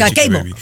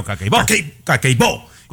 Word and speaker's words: Kakeibo: Yo creo que tengo que hacer el cakeibo, siempre se pronuncia, eh, siempre eh, se pronuncia la fuerza de voz en la última sílaba normalmente. – Kakeibo. no, ¡cakeibo!